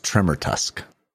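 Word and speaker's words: Tremortusk: It's pronounced Tremor Tusk.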